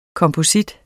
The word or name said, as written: komposit